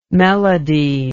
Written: melodie

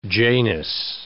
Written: Janus

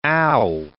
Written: ow

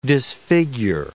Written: disfigure

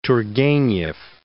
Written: Turgenev

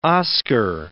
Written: Oscar

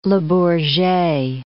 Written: Le Bourget

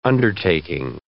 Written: undertaking

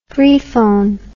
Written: freefone

